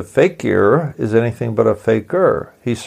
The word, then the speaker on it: fakir: Faker is a Muslim and Hindi-derived word, and it's pronounced about the same as is the word faker, F-A-K-E-R, in English. And I should say that the faker in Muslim is spelled F-A-K-I-R.